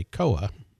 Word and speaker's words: Koah: And I think I'm pronouncing this correctly when I say Koah. – K-O-A-H. – Yes.